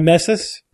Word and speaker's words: Ramesses: Pepper was used in the mummification process of Ramses II. I don't know. – You have to say about Ramses. – Ramses. – Isn't it pronounced Ramesses? – Yes, you're right. And homeostasis. Right. – Ramesses? – Ramesses.